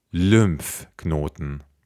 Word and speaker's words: Lymphknoten: Wir haben ein Y nach dem L und das wird immer Ü ausgesprochen, wie Syrien. Ja? Lymphknoten.